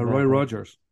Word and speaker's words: Roy Rogers: Ja, Roy Rogers.